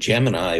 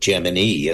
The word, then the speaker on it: Gemini: NASA, within the walls of NASA, the long E pronunciation became adopted, Gemini, as opposed to Gemini.